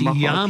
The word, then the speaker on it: Yamaha: Yamaha. Did I say that right? It's Yamaha. Yamaha.